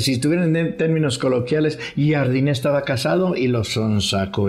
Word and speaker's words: Jardine: Pues estaba comprometido estaba comprometido Jardiné, como se le pronuncia Jardiné con el San Luis, estaba toda madre entrenando y todo.